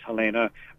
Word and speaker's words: Helene: Her father was German, so it looks like Helene, but we pronounce all the letters, so it's Helena.